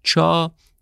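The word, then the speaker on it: چا (茶): چا تلفظ کانتونی گیاه چای امروزیه.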